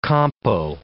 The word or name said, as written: compo